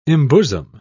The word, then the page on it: embosom: British English